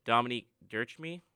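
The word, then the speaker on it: Dominique Ducharme: Also, last point on the NHL, Dominique Dirschmy, is that how you pronounce it? – Yes.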